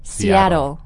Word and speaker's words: Seattle: Seattle. – Seattle.、Mm-hmm. – Seattle. – Mm-hmm. And there are two T's, but again, we say them really quickly. Seattle. – Seattle.